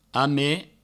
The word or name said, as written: ame